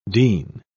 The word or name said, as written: dean